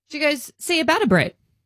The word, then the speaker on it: ciabatta: It's ciabatta. – Ciabatta bread. – Ciabatta bread, because that's how it's spelled. C-I-A-B-A-T-T-A.